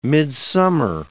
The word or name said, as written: midsummer